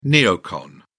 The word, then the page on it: neocon: British English